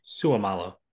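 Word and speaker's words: Seumalo: Suamalu.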